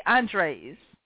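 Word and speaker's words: Andrzej: but he spells Andrew, like, different. It's like A-N-D. – But they pronounce it different because they say Andres.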